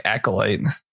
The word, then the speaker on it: Acolyte: I thought it was like Acolyte. – Acolyte.